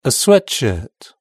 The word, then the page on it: a sweatshirt: British English